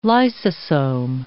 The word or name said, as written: lysosome